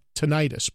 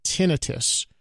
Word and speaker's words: tinnitus: I often thought it was called tinnitus, but it's actually pronounced tinnitus.